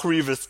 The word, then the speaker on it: Revis: Doch, Revis. Gut, ich wusste nicht, ob das nicht wie, als wäre es ein W ausgesprochen wird.